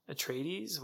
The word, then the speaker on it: Atreides: Paul Atreides, how do you say his name? – Yeah, Atreides.